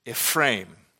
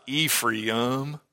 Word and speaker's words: Ephraim: It is Ephraim. It is not Ephraim. Do not say that, please. So it is not E-P-H-R-I-U-M. The A and an I form together, Ephraim. So if the way I say it bothers you, repent.